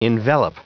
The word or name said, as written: envelop